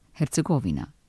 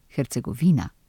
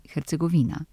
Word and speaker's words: Hercegovina: Ime te pokrajine slišimo pogosto izgovorjeno s takšnim naglasom Hercegovina ali pa Hercegovina. Medtem, ko je njeno slovensko poimenovanje takšnole, Hercegovina. Podobno kot ime Ukrajina, naglašamo tudi Hercegovina.